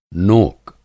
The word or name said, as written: नोक